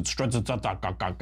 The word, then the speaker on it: Strzok: The new texts show FBI agent Peter Strzok, I pronounce it Strzok, because it's like 75 consonants and no syllables,